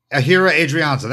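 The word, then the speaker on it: Ehire Adrianza: You had Adrianza and did I pronounce his name? If I already forgot what his name was? Ahira Adrianza, that's right. – You got it.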